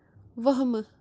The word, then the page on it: wahmu: Kashmiri